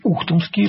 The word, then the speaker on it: Ухтомский: И сегодня я хочу рассказать, с одной стороны, известный, с другой стороны, в широкой публике фактически неизвестный, Алексей Алексеевич Ухтомский. Сразу говорю, что ударение на первую букву, это он.